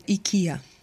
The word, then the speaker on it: IKEA: Оказывается, эта компания в Швеции, где она и появилась изначально, произносится IKEA. То есть по-русски, можно сказать, мы даже ближе к оригиналу произносим это слово, чем американцы.